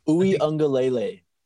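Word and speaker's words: Uiagalelei: But Ugalele, did I say that right? – Ui Ungalele. – Uga Alele.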